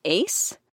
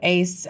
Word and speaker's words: AIICE: And then you moved on to starting what our main topic is the Alliance for Identity Inclusive Computing Education, or is it pronounced ACE? – Yeah, so ACE, uh, and actually, I should say, uh, Dr. Nicki Washington, who's in computer science, really uh, led that effort.